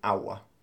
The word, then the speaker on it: agua: And water is pronounced agua. Even though it's spelt A-G-U-A, it's like a W, isn't it? Agua. – Agua. – But you don't say the G.